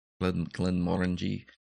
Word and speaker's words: Glenmorangie: Glenmorangie.